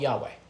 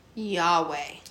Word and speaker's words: Yahweh: the God is called Yahweh. – Yahweh.